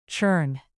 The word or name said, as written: churn